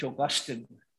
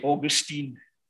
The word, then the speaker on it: Augustine: Saint Augustine. That's how the British, and that's how we learn to pronounce it. The Americans say Augustine. Anyway, it's wrong.